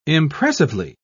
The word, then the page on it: impressively: British English